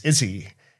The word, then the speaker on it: Issy: And it's Izzy, ISSY. So Izzy.